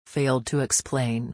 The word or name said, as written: failed to explain